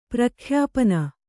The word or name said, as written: ಪ್ರಖ್ಯಾಪನ